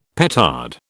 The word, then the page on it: petard: British English